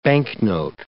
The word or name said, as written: banknote